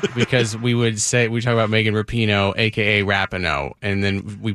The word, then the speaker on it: Rapinoe: It's pronounced Rapinoe.